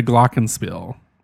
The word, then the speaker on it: Glockenspiel: No one ever died from refusing to say the Pledge of Allegiance or play the Glockenspiel.